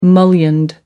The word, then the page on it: mullioned: American English